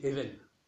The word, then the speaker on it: Eben: La bonne prononciation, c'est Eben, puisque c'est un B, comme ça.